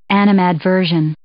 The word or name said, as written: animadversion